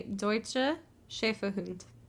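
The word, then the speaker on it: Deutscher Schäferhund: So, the German term would be Deutsche Schäferhund.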